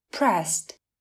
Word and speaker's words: pressed: Pressed viết là P R E S S E D. e d ở từ này được phát âm là T, pressed.